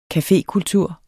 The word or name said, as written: cafékultur